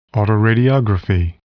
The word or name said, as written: autoradiography